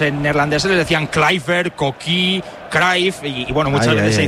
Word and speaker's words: Cruyff: Pero Quique Guas siempre pronunciaba Johan Cruyff. ¿No? – Cruyff. – Lo pronunciaba como teóricamente lo pronunciaban los holandeses.